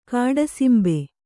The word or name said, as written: ಕಾಡಸಿಂಬೆ